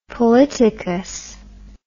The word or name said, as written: polytocous